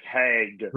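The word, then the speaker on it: Hägg: As far as I know, it's just the straight up easy Robert Hag. – Okay, because it's the two dots above the A that always throw me off.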